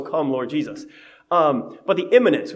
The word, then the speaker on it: immanence: And it's immanence, I-M-M-A-N-E-N-C-E. I-M-M-I, immanence, if I could try and pronounce that I, is like coming soon, right?